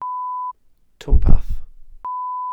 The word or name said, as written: twmpath